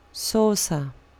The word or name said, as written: saucer